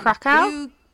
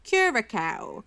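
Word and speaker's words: Curaçao: Krakow? – Curacao.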